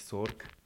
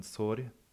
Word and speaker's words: sorg: Also nicht Sorg, sondern Sori.